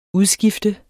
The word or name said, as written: udskifter